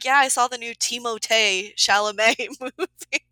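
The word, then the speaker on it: Timothée: So I was like, it is Timothy Chalamet, right? Which then led me to, that's actually Moji? Not how you pronounce his name. – Yeah. – It's Timotei. It's like, I'm not going to do that, but it's Timotei Chalamet.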